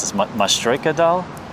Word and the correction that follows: Matryoshka: Matryoshka, yeah, vis like, yeah, that's really something.